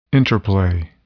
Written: interplay

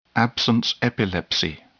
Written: absence epilepsy

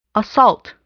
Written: assault

